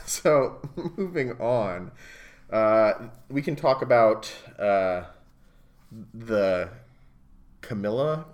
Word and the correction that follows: Camellia: Camellia, I thought.